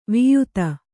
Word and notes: ವಾಯಿದೆ: vāyide

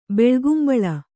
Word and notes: ಬಿಳಿಗುಂಬಳ: biḷigumbaḷa; Kannada

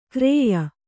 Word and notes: ಖರೆಯ: Kannada

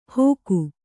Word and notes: ಹೋಗು: kannada

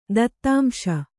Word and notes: ದತ್ತಾಂಶ: Kannada

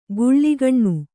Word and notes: ಗುಳ್ಳಿಗಣ್ಣು: Kannada; giḷḷigaṇṇu

